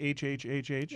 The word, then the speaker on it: HHhH: Do we pronounce it HHHH? – Yes. – Okay, even though one of the H's is not capitalized.